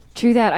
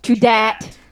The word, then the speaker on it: true dat: True that. I did omit. – No, I'm sorry. Did you just say true that? – Oh, no. Is that? – It's spelled with a D, dat. – True dat.